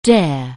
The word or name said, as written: dare